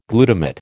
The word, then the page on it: glutamate: American English